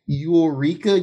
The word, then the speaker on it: Yooreka: Anyway, but yeah, so Bandai Namco, some company named Eureka Games. I've never heard them. It's not even spelled like Eureka. It's spelled like Y-O-O-R-E-K-A Games.